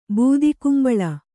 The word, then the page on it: ಬೂದಗುಂಬಳ: būdagumbaḷa